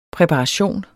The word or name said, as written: præparation